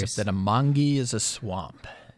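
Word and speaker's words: Mangue: We're going to start off with Manji Beat, which was a genre, and this was considered Manji. Am I saying it right? – Mangi Beat.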